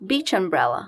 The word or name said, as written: beach umbrella